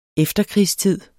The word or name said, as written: EFTERKRIGSTID